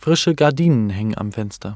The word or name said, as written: Frische Gardinen